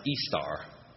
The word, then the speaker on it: Ishtar: It's actually I S H T A R, pronounced Istar, and shows that again this is tied to the East Semitic, Akkadian, Assyrian, and Babylonian goddess of fertility, love, war, and sex.